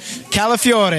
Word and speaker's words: Calafiore: What is up, am I saying that right? – Calafiore. – Calafiore, yes. – Roll that R.